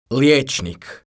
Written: lijecnik

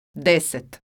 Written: deset

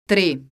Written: tri

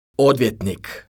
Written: odvjetnik

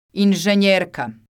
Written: inzenjerka

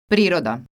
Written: priroda

